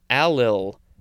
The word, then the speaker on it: allyl: Next is, I think it's pronounced allyl, A-L-L-Y-L.